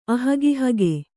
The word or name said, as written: ಅಹಗಿಹಗೆ